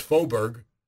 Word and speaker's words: Faubourg: It's pronounced Faubourg.